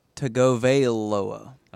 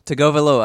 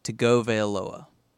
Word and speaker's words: Tagovailoa: Tagovailoa, I think, is how you say it. You got to enunciate the A part of the veil and the oa. And I think it's Tago. Like Tagovailoa.